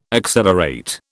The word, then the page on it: accelerate: British English